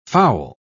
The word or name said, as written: fowl